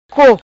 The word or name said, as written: Co